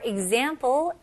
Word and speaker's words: example: 네, 여기서는 발음을 example. Example. E-G처럼 시작해서 그 다음에 Z로 넘어가는 거예요. Example. Example. Okay. 강세가 어디에 들어갈까요? 다시 한번 들어보실래요? Example. 네, 맞아요. Z에 들어가죠?